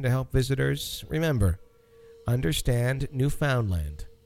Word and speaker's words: Newfoundland: It's pronounced Newfoundland, with the emphasis on land. Newfoundland.